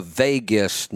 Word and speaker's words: vagus: I'm not sure what the proper tense is. It's the vagus nerve, V-A-G-U-S. And I'm not always sure which tense you use, vagal, vagus, I don't know.